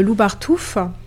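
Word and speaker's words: Lubartów: Donc c'est la ville de Loubartouf, qui s'écrit Loubartov, O-W, qui est une ville située à 30 km de Lublin en Pologne.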